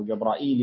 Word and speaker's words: جبرائيل: جبرائيل، يعني لا تنطق جبريل وجبرائيل على فكرة، هذا صحيح وهذا صحيح، بس الشيعة يقولوا جبرائيل على طول ما يقولوش سيدنا جبريل عليه السلام، تمام.